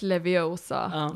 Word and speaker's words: Leviosa: It's Leviosa, not Leviosa.